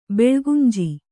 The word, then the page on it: ಬೆಳ್ಗುಂಜಿ: kannada